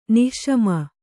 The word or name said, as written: ನಿಸ್ಸೀಮ